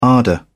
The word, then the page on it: ardour: British English